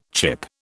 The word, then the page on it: chip: British English